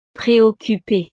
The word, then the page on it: preoccupe: frances